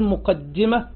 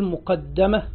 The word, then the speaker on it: مقدمة: قال الشيخ مقدمة، كلمة مقدمة تنطق في لغة العرب بطريقتين، إما أن تقول مقدمة بكسر الدال مع التشديد، وإما أن تقول مقدمة بفتح الدال مع التشديد.